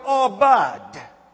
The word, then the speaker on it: abad: The first one is the Hebrew word abad. It is spelled A-B-A-D.